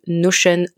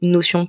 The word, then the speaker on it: Notion: En l'occurrence, on va parler de Notion ou Notion pour les personnes qui prononcent euh, en français.